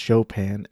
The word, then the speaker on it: Chopin: And it's pronounced Chopin. And I almost pronounced it Chopin earlier in this episode.